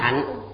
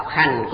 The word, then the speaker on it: hành: Chữ hành này thở xưa đọc là hạnh, đọc âm phá, dùng như động từ. Người thời nay không mấy chú trọng những thứ này, đọc hành cũng được.